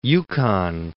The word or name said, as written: Yukon